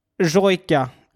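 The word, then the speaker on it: Sójka: Skalniak Jojka. Jojka. Mm. Exakt så ska man uttala det.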